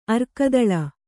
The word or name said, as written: ಅರ್ಕದಳ